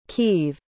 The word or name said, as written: kieve